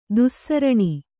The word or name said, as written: ದುಸ್ಸರಣಿ